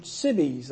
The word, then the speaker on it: Sibbes: I was calling him Richard Sibbs. I have to apologize for that. It's Richard Sibbes. I looked that up and got the pronunciation of that.